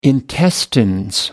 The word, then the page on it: intestines: British English